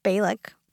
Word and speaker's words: Balak: So right before this, Balak, did I pronounce that correctly? – You got it.